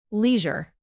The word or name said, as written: leisure